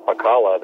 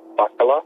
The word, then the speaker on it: Pakala: You just say it just like Pakala, that place over there on Kauai. I believe there's a surf spot over there called Infinity. – Well, that's how you say my name.